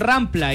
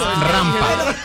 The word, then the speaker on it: rampa: No, es que sí, pero dijeron rampla y no se dice rampla. – Se dijo rampla y es rampa.